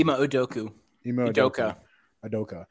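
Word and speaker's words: Ime Udoka: Ima Udoka.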